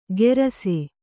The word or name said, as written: ಗೆರಸೆ